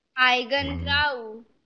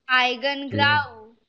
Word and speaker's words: Eigengrau: And the name is Eigengrau. Let me see if I pronounced it correctly. – Eigengrau. Eigengrau. – Oh, Eigengrau. – Eigengrau. Mm. – It's a German.